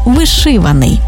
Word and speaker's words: вишиваний: Отже, цілодобовий та щодобовий, 100%-овий, ринковий, одноразовий та багаторазовий, наскрізний та вишиваний.